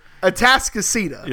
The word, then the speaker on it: Atascocita: Houston area, odd pronunciations, kind of like Guadalupe and others. That's Atascocita. – I should have practiced that one. No. Atascocita. – That's right. You got it.